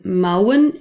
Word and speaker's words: mouwen: And remember that the au in mouwen is pronounced kind of like in the word how.